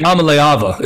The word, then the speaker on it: Iamaleava: Iamaleava is how he would say it.